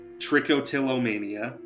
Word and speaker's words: trichotillomania: It's actually a disease called trichotillomania. I think that's the correct pronunciation. – I believe so.